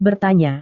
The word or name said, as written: bertanya